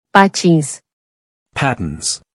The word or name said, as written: Patins